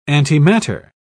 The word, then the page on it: antimatter: British English